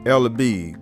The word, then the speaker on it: Ella B: I noticed you named the farm Ella B. Did I pronounce that correctly? – Yes, sir.